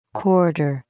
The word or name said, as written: quarter